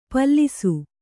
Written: ಪಾಲಿಸಿ

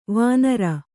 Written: ವಾಣರ

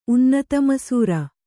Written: ಉನ್ನತಮಸೂರ

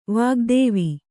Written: ವಾಗ್ದೇವಿ

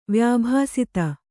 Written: ವ್ಯಾಭಾಸಿತ

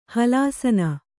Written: ಹಲಾಸನ

